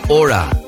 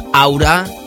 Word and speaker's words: Aura: Ellos ahora remezclan a una jovencísima cantante, tan solo 15 años, que se llama Ora. Así se pronuncia en inglés en español diríamos aura, pero atención porque se escribe A-U barra R-A...